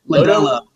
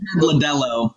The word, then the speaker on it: Lodolo: So, Nick Lodulo? Lodolo? – Lodolo. Lodolo. Like, rhymes with the beer, Modelo.